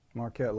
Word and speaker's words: Marquette: He says, pointing to a recent Marquette law poll. Did I say that right? – Marquette Law Marquette, School. Marquette Law School. I didn't know if there was some other way to pronounce that.